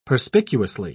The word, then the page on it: perspicuously: British English